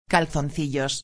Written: calzoncillos

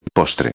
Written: postre

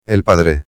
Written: el padre

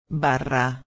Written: barra

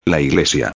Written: la iglesia

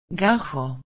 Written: gajo

